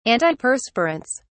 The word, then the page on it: antiperspirants: British English